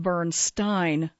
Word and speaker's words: Bernstein: And it's actually pronounced Bernstein with a long I. My apologies for the previous mispronunciations.